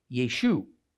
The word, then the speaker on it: Yeshu: So in the Galilee, the way his name was spoken sounded like Yeshu. In Judea, it sounded like Yeshua.